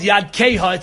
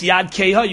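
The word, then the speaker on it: Yad Keha (ידכה): It's spelled Yad Keha. It's spelled Yud Dalid Chaf Hey, not Chaf Sofit, but Chaf Hey, to tell you that it's Yad Keha.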